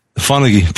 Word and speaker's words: Fonagy: Fonagy, Peter Fonagy.